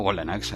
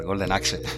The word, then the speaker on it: Golden Axe: Eh, pero bueno, es un juego que ya hemos hablado, Golden Axe. – Eh, perdón, perdón que lo he dicho mal, Golden Axe.